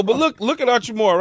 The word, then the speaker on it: Hachimura: Now look at how you say it, Uchimura. – Hachimura.